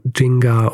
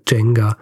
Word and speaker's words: Nzinga: Aussprache ist, glaube ich, grundsätzlich sowas in Richtung Jinga oder Jenga. Haywood spricht auch manchmal von Jenga, aber ich glaube, das hängt einfach nur davon ab, wie schnell sie spricht.